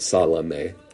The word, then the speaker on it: Salome: Other writers say that the language speaks of her, this little girl, Salami, is the best way to pronounce it, Salome,